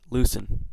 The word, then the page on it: loosen: American English